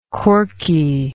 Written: corky

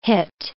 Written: hit

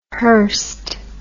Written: hyrst

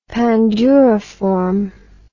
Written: panduriform

